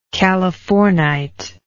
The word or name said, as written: californite